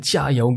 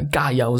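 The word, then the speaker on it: Gaiao (加油): Und wir haben uns für die kantonesische Aussprache entschieden, weil die Wurzeln unserer Akademie nach Guangzhou, also Kanton, in Südchina reichen. Und da spricht man natürlich kantonesisch. Und deswegen ist es relativ naheliegend, dass wir Gaiao sagen und nicht Jiayao.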